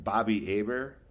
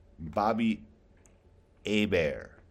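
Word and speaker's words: Bobby Hebert: Bobby Aber. – Who are you going to take? – The H was silent. Spelled the same. H was silent. T was silent.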